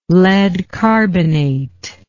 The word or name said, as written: lead carbonate